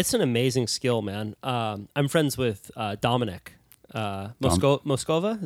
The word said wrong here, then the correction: Mosquera: Is that how you pronounce his last name? – Mosquera. Yeah, Dom's a good friend.